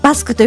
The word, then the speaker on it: BASK: バスク